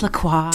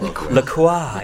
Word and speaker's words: LaCroix: It's pronounced LaCroix. – Talking about nostalgia. – LaCroix, yes, excellent.